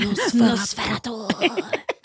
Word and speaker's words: Nosferatu: Is that the dying pronunciation? – Yes, that is the correct pronunciation. – Nosferatu.